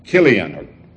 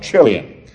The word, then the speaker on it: Chilion: The names of the children were Mahlon and Chilion. That's the Hebrew pronunciation. Perhaps you might prefer to call it Chilion.